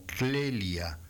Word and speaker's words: Clelia: Y en la carpeta de rarezas tenemos verdaderas joyitas, algunas difíciles de pronunciar, como Clelia, CL, Clelia, Santa Clelia. No Celia, le ponemos a Celia una L en medio, Santa Clelia, felicidades a todas las Clelias.